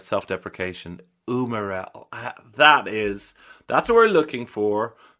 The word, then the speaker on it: umarell: I don't, But if not, Umarell,